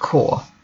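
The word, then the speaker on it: corp: Land core, short for corporation or company. The final P is not pronounced.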